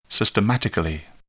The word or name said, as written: systematically